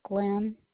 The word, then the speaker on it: Glynn: The name on the account is Glenn Davis, G-L-Y-N-N.